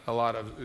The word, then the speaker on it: aletheia: The word truth is the word aletheia, or aletheia, a lot of is the correct pronunciation.